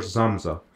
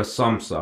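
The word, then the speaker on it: Samsa: تو این کتاب داستانه یه نفر هست، گرگور زامزا یا سامسا، حالا من آلمانی تلفظ میکنم، یه روز بلند میشه و میبینه که یه آدم شده حشره، شده خرمگس.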